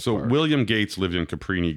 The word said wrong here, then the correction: Cabrini: Green. – Cabrini. – Yeah, what's that? – Brini. Cabrini. – Cabrini? – Brini. B-R. – Have I been saying that wrong my entire life? – Caprini. You're saying Caprini. It's not like Capri.